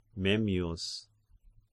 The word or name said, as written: Memmius